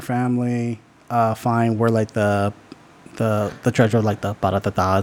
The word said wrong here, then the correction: Parapata: Parapata. – Like yeah, paratata. How do you say it? – Parapata.